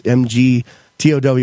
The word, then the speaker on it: MGTOW: And it's about the topic of MGTOW, which I think is the coolest acronym ever of how it's pronounced out loud. It's MGTOW, and it stands for Men Going Their Own Way, which is not a song by Fleetwood Mac.